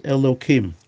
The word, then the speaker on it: Elohim: In that case, you pronounce it as Elohim, or properly if you say the whole sentence.